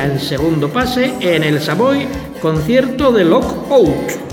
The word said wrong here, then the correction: Lookout: Lookout. – Siempre lo digo mal, pero bueno.